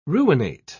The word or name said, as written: ruinate